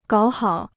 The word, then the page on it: 搞好: gǎo hǎo